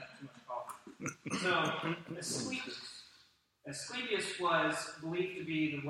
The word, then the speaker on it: Asclepius: The first one was dedicated to worship, I knew I was going to mess up the pronunciation of this, the Greek god Asclepius. Asclepius. I'm butchering it, I'm sure. Asclepius, is that what it is? Asclepius, that's what it is.